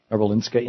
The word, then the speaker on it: Karolinska: Well, he lives up the road in McLeod Ganj, but where the Karolinska Institute, am I saying that right? – Yes.